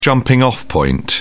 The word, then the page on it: jumping off point: British English